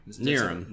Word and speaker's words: Knierim: Knierim. Yes, that's a common mistake. It's okay. Yes, the K is silent.